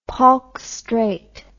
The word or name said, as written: Palk Strait